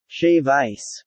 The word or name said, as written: shave ice